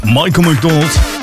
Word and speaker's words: Michael McDonald: Michael McDonald.